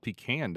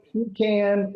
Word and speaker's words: pecan: P-E-C-A-N. The nut. – P-E-C-A-N. I live in the South, so it's called pecan down here, right? – it's pecan or pecan.